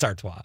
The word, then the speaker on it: Artois: Am I saying that right? Artois. Artois? – I don't know. – It's Artois.